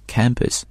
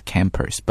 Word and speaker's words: campus: 校园 campus, it's not campus, but campus,